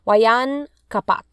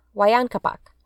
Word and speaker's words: Huayna Capac: So let's talk about the supposed last Inca, Waya, uh, oh my god, I can never say his name, but it's um, Huayna Capac. Huayna Capac.